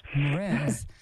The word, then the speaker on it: Mraz: Mraz.